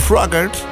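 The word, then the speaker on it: Froggatt: Voor de input geef ik de Red Balloon van Raymond Fogar. Raymond Froggert. Zo mag ik hem op zijn Amerikaans uitspreken.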